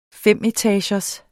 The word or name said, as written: femetagers